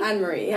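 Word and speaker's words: Anne-Marie: right now, like Yoria Smith, IMDb, Ray Black, Lady Lucier, Anna Marie. Do I pronounce that correctly? – Yeah. – It's Anne Marie? – Anne Marie, yeah.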